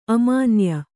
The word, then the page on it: ಅಮಾನ್ಯೆ: amānye